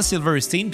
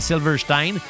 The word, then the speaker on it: Silverstein: Puis que moi, je leur disais ben on appelle on prononce ça Stein parce qu'en allemand S-T-E-I-N c'est Stein. Tu, sais, tu dis-tu Ramstein, tu dis-tu Frankenstein Non, c'est Frankenstein et Ramstein. C'est la même chose, c'est Silverstein.